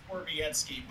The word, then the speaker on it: Borowiecki: Borowiecki. It's pronounced Borowiecki, but we call him Borokoppen.